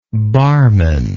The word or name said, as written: barmen